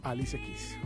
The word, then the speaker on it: Alicia Keys: En la ambientación musical nos acompaña hoy Alicia Keys. ¿Así se pronuncia? – Alicia Keys, justamente.